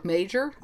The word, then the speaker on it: Magyar: No, apparently the G-Y sound, because I always thought it looked like Magyar or Magyar, but the G-Y sound is an individual letter of the Hungarian alphabet, and it's pronounced like we do a soft J sound.